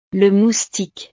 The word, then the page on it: le moustique: frances